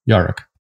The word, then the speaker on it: Yarak: Y-A-R-A-K is the way it's spelled. A falconry term, meaning that when your falcon is super alert, hungry, but not weak, and ready to hunt. So that's very intentional. – How do you spell that word? That's such an interesting idea. – Yeah. Y-A-R-A-K. Yarak. – Yarak.